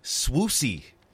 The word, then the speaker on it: Swoosie: I did some Googling about Swoozy Kurtz. – Okay. – Turns out it's actually Swoosie. – Swoosie. – Swoosie, which it specifically says rhymes with Lucy rather than woozy.